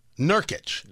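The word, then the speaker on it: Nurkić: But if you didn't see it, is it Nurkic? Is that how I pronounce his name? – Nurkic. – Nurkic. Yes. Okay.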